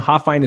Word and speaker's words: Hofhine: hofhine is spelled h-o-f-h-i-n-e